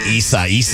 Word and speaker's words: Isaias: So that Hurricane Isaias, that's how you pronounce that name, forcing a lot of stuff to get canceled in its path this weekend.